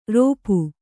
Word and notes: ರೋಪು: rōpu

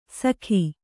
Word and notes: ಸಖಿ: kannada

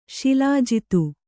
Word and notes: ಶಿಲಾಜಿತು: śilājitu

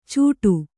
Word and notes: ಚೂಟು: cūṭu

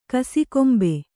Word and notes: ಕಸಿಕೊಂಬೆ: kasikombe

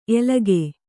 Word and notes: ಎಲಗೇ: elagē

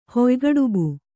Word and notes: ಹುಯ್ಗಡುಬು: huygaḍubu

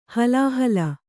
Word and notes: ಹಲಾಹಲ: halāhala

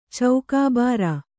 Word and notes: ಚೌಕಾಬಾರ: Kannada

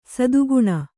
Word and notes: ಸದ್ಗುಣಿ: sadguṇi; Kannada

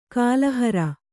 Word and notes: ಕಾಲುಹಾರು: kāluhāru

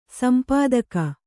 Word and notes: ಸಂಪಾದಕ: sampādaka

